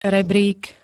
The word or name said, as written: rebrík